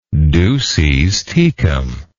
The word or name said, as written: duces tecum